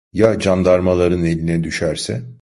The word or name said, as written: eline